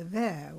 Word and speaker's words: WIR: One of the most successful currency systems is called the VIR, which is spelled WIR, and that's in Switzerland.